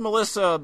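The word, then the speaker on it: M3LL155X: It's spelled like, it's pronounced Melissa, but it's spelled like M3375X or something.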